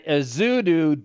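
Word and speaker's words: Ezeudu: Let me look up the pronunciation. – Ezidu. – Ezidu. – Ezidu. – Is the preferred Ezidu. – You do the voodoo that Ezidu do so well.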